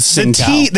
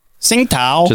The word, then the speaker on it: Tsingtao: Well, we're going with Tsingtao. – The T is usually silent at the beginning of something like that. – That sounds like the villain that was in Rush Hour 2. – Tsingtao.